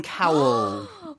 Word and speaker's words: Cowell: originally uh, taken to the record label by the famous Simon Cowell. – well, Simon Cowell was Simon Cowell? – Cowell. – Cowell이라고 발음해야 되거든요. Cowell.